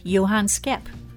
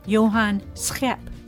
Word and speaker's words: Schep: Now, that is the English version of the pronunciation. It's spelled S as in Sam, C H E, P as in Peter. Doesn't look too hard, but he would say Johan Schep. A little harder for us English speakers.